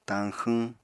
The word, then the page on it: tañxwñ: apatani